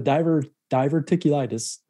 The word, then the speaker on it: diverticulitis: So the diverticulitis, is that pronounced correctly? – Yep.